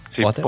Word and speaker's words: Podder: Y por suerte han venido a nuestro rescate empresas como la que tenemos hoy, Podder, que se pronuncia así, ¿no? ¿Podder? – Sí, Podder. Podder.